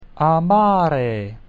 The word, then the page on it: amare: Latin